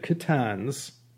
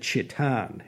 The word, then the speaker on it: Khitans: Our focus today, the Khitans, I checked a few sources, and this was the most common pronunciation from all online dictionaries I checked. Dan Carlin uh, pronounced it Chitan in his superb Wrath of the Khan series on hardcore history. In Mandarin, they were known as the Chitan people.